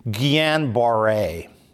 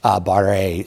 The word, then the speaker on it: Guillain-Barré: It's G-U-I-L-L-A-N, I think, or maybe it's A-I-N. But the double L there is sort of a Y sound, so Guyan. And then the B-A-R-R-E with an accent on the E. Guillain-Barré syndrome.